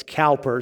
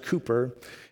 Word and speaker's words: Cowper: If you see his name, sometimes it looks like it's Cowper, C-O-W-P-E-R, but it's pronounced Cooper.